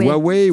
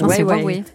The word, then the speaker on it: Huawei: C'est quoi la prononciation ? Je vois sourire, c'est une canadienne. – Huawei. – Huawei, Huawei, je ne sais plus. – Huawei.